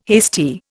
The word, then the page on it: hasty: Indian